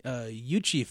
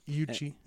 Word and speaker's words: Iuchi: Then there's the Yuchi family, and that's spelled I-U-C-H-I. – Yuchi, like a Yuchi, okay, gotcha, like a Y sound, sort of.